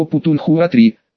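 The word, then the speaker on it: путунхуа-3 (普通话): Сами китайцы называют его путунхуа-3, с ударением на последний слог.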